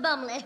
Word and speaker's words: Bottomley: And I'm James Elginham Bottomley, pronounced Bumley. The tea is silent, unless you drink it out of a saucer.